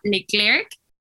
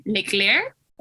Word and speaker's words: Leclerc: Y aquí mencionas también a Leclerc, digo, perdón, Leclerc.